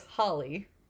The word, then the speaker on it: Halley: Because Haley's Comet is spelled H-A-L-L-E-Y. – What? – Yeah. So it's Holly.